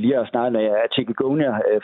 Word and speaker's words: Chikungunya: Så Chikungunya, nu fik du sagt det først, det var dejligt, for jeg vidste ikke, hvordan jeg skulle udtale det, men det lyder godt, når du sagde det så.